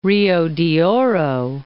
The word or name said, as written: Rio de Oro